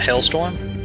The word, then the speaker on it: hailstorm: Is it not Hailstorm? – It is Hailstorm, but he called it Hailstrom. – Hailstrom, okay. – Specifically said Strom. It sounds like Maelstrom.